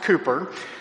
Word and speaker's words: Cowper: Now, it's pronounced Cooper, but you've actually seen his name in our bulletin before. It phonetically, it looks like Cowper, C-O-W-P-E-R, but it's pronounced William Cooper.